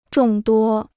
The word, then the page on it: 众多: zhòng duō